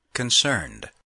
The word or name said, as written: concerned